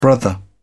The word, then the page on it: brother: British English